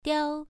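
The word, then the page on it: diao: tone 1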